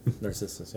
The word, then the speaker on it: Narcissus: Narcissus, yeah.